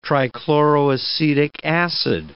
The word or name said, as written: trichloroacetic acid